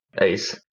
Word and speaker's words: Uys: So my name is Corneile Ace, my last name, spelled kind of funny. Nobody can really pronounce it until I actually have to tell them how to pronounce it.